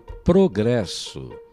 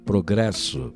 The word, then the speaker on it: progress: E na língua inglesa, a palavra progresso, que tem praticamente a mesma grafia, é dita assim. – Progress. – Você ouviu? Acentuação na primeira sílaba.